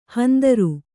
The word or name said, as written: ಹಂದರ